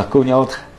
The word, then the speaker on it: 狗尿台: 而同样是在十三岁那年，开始被文革风暴席卷的贾平凹，也和狗尿台一样。过早的品尝了生活的苦涩。 – 作为一个贯穿线索的主人公，叫狗尿台。 – 狗尿台，陕西话应该怎么念？ – 呃，狗尿台。